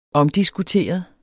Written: omdiskuteret

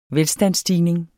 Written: velstandsstigning

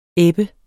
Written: ebbe

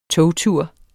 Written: togtur

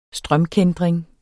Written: strømkæntring

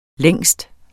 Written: lang

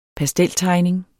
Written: pasteltegning

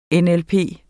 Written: NLP

